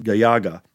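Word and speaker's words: Galaga: First of all, thank you for telling us how to pronounce it, Gayaga. And that's going to be the way I pronounce it from now on. Since you're an authority on the Japanese language. – I love how when he gets to like Japanese-ish words, he actually pronounces them as like the Japanese way.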